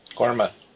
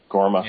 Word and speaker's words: Gorma: Yeah, we get a little bit more into I know I'm going to say it wrong, but the Gorma? Gorma? – Gorma. – Gorma. – Yeah, okay. I know how they spell it, but whenever they say it in the show, it sounds like they're saying Gorma.